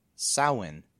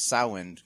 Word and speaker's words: Samhain: The standard Irish pronunciation of it is Samhain. There's no M pronouncing pronouncing it. It's like Samhain, kind of like the ow and cow.